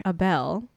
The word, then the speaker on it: Abel: I smell like a bell. That's spelled A-B-E-L.